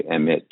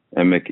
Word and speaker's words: Emich: People often say Emich, but the CH is pronounced like a K. You could just think of the German city Munich, which also ends with a CH. And in fact, Emick is a German name, originally spelled E-M-I-C-H.